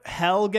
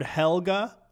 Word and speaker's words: Helge: Helge Granz, is that the pronunciation here? I've heard it a couple times, but I've already forgotten. – I've heard Helge. I've heard Helga. – Helga. – Like, hey, Arnold. And then I've also heard Helge. – Hey, Arnold. – I know that it's Grans, though.